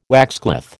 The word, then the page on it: waxcloth: American English